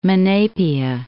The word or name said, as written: Monapia